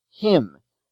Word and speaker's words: hymn: In this lesson, we take up the many words of Greek origin in which Y stands for short I, as in cyst, C-Y-S-T, him, H-Y-M-N, gym, G-Y-M, symbol, S-Y-M-B-O-L, system, S-Y-S-T-E-M, mystery, M-Y-S-T-E-R-Y, etc.